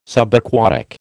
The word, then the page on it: Subaquatic: American English